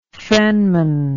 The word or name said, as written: fenman